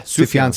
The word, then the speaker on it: Sufjan: Sufjan? – Yeah, Sufjan Stevens.